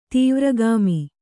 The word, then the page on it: ತೀವ್ರಗಾಮಿ: tīvragāmi